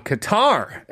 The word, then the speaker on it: Qatar: For Jamie in Doha, Qatar, as I now know is the proper pronunciation.